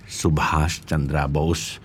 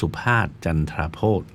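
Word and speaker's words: Subhas Chandra Bose: มีการรื้อฟื้นทุนสุภาชจันทราโบสซึ่งคนไทยจะออกเสียงประมาณว่าทุนสุภาชจันทราโพธ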